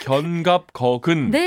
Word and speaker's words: 견갑거근: 이름하여 견갑 걷은 스트레칭. 스파르타! – 견갑거근. – 네. 어, 발음이 어려운데 음.